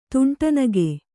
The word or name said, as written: ತುಂಟನಗೆ